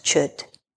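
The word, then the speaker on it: Chöd: It's spelled C-H-O with two dots over the O-D, but it's pronounced CHUD, CHUD, which means severing or to cut away that which is no longer serving or working for us in our liberation.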